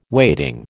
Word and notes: waiting: American English

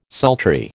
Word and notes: sultry: American English

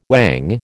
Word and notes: whang: American English

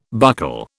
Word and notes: buccal: British English

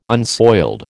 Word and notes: unsoiled: American English

